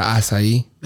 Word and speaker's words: acai: The acai.